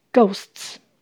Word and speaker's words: Ghosts: Afraid Afraid Ghosts 鬼的复数形，ts 发 ts 的声音，h 不发音，Ghosts Ghosts。